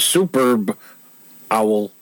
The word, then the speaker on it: Superb Owl: You got to get the B at the end of super. So it's the Superb Owl Party. So instead of being the Super Bowl Party, it's the Superb Owl Party.